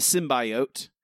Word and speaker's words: symbiote: You know, like it it is like okay, Spider Man, and then Spider Man gets the sim the symbiote. Symbiote. That's how it's pronounced. That's how it's pronounced. He, that's he, that's that's canon now.